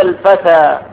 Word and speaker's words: الفتى: يعني الفتى كتبت بما يسمى بالألف اللينة، كتبت ياء، ولكن العبرة بما تنطق وليست العبرة بما تكتب. نجا الفتى هنا آخره ألف.